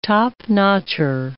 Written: topnotcher

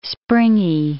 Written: springy

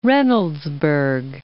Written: Reynoldsburg

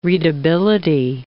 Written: readability

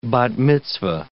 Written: bat mitzvah

